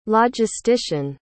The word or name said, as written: logistician